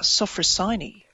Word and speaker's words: Sophrosyne: Ah, Sophrosyne rhymes with Hermione.